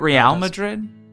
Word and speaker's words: Real Madrid: Isn't it Real Madrid? – I just didn't want to confuse the American audience because it's spelled R-E-A-L.